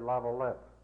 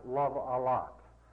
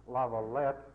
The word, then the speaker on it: Lavalette: He tells me that a lot of people mispronounce this word, Lavalette. You're supposed to have it let lava or something like that. Not love a lot.